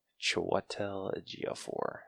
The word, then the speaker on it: Chiwetel Ejiofor: Chiwetel Ejiofor, who played Baron Mordo.